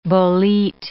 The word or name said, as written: bolete